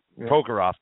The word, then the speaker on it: Prokhorov: And I don't buy it for a second that – is it Pekarov? Is that how you say it? Prokhorov.